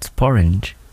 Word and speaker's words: sporange: And we found out that sporange rhymes with orange.